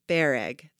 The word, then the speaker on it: bereg: My family is Armenian, so there's always some sort of rice pilaf and baklava, Armenian spelling of baklava, or boreg, or I see I already said it wrong, pronounced bereg.